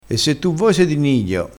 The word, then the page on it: nidio: dialettale